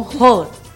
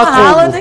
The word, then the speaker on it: Haaland: É porque eles pronunciam Roland.